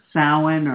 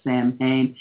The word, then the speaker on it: Samhain: My favorite cross-quarter celebration is Samhain, or a lot of people mispronounce it as Samhain, or All Hallows Eve, which is a day that we return to the guidance of our ancestors.